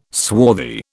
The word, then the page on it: swarthy: British English